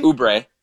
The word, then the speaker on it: Oubre: Is it pronounced Oubre? Kelly Oubre? – Oubre.